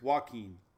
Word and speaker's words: Joaquin: Joaquin. Joaquin. Not Joe Queen.